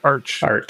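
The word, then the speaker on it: phparch: So if you go to PHP Arch, am I saying that right? Arch like March.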